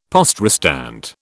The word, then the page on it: poste restante: British English